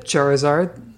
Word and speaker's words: Charizard: Yep, Charizard.